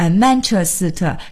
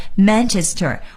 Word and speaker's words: Manchester: 的这个发音和中文的意词啊，有的时候非常的接近，比如说 Manchester 就是曼彻斯特，但是同时呢，我们也一定要注意，在我们说这个英文词的时候呢，千万不要带上中文的调，也就别说曼彻斯特，这是不行的，我们要说 Manchester。